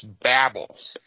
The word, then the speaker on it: Babel: And it's pronounced Babel.